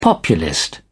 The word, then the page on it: populist: British English